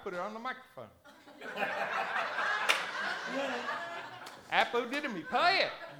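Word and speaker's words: apodidomi (ἀποδίδωμι): Apodidomi. It is, apodidomi. Well, I did it phonically so I'd pronounce it right. It's apodidomi.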